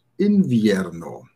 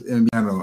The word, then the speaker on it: invierno: Genau, eigentlich wird es invierno ausgesprochen. Invierno, ja, also das W, das wird wie invierno, wie ein B eigentlich. Invierno ausgesprochen, das B wie ein B, bei dem die Lippen sich nicht berühren, zum Beispiel Trachar, Trachar, Invierno, Trachar.